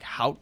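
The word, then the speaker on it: haute: It's hout. Like, I was like, out there, like, you guys call this, like, hout dogs?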